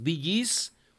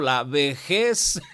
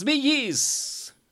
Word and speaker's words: Bee Gees: Ahorita me estaba acordando de un ex compañero que pues ya lamentablemente falleció, pero cuando anunciaba este grupo, los Bee Gees, como se escribe B-E-E-G-E-S, decía el grupo La Vejez.